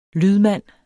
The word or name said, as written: lydmand